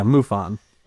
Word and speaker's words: MUFON: But I guess what was MUFON? Is that how they pronounce that? – Yeah, MUFON.